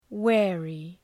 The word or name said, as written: wary